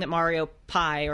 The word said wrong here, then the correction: Pei: or actually I think it's pronounced Pei. Thank you to a listener who told me that. – Oh, really? – Yeah, it's Pei, actually.